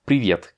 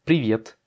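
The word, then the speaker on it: привет: Privet, privet. J'accentue sur le I. Normalement, ça devrait pas te choquer parce que, comme elle est sur la dernière syllabe, bah, ça correspond à ce qu'on connaît en français.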